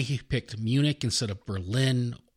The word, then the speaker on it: Berlin: But you put the emphasis on the second syllable in Berlin, so yeah.